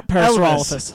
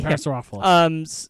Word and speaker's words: Parasaurolophus: Parasaurolophus. – Thank you, Parasaurolophus.